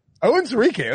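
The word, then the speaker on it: Onwuzurike: Amari Rogers and Levi Onwuzukworki. I didn't get that right. How do I pronounce it? – Owen Sirike.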